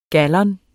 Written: gallon

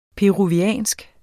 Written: peruviansk

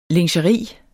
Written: LINGERIETS

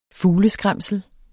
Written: fugleskræmsel

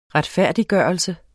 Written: retfærdiggørelse

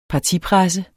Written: partipresse

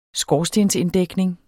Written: skorstensinddækning

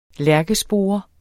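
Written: LÆRKESPORE

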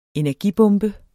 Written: energibombe

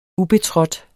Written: ubetrådt

